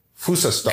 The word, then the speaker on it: Frühstück: Das Frühstück.